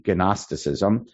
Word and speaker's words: Gnosticism: But even in Eretz Yisrael, there was Gnosticism, spelled with a G, Gnosticism.